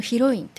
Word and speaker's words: 主人公: タイトルは色彩の主人公と書いて、色彩のヒロインというタイトルです。